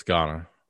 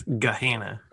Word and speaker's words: Gahanna: It's in Ghana, which I guess is Gahanna. – No, it's Ghana. – It's Gahanna. – Gahanna, which is in Central Ohio, the first Central Ohio-owned black brewery.